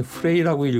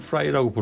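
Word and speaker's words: Frey: 글렌 프라이가 FREY이기 때문에 글렌 프레이라고 읽어야 되나? 뭐좀 고민했는데 어느 다큐멘터리 보니까 돈 헨리가 글렌 프라이라고 부르더군요.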